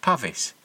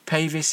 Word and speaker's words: Pavis: I've since learnt that this is more accurately pronounced Pavis, but I've always used the long vowel sound because I assumed that it was related to pavement, which is the British name for sidewalk.